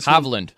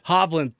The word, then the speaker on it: Hovland: I think it's Hovland. There's a C. Smith who's Hovland. – Hovland, thank you.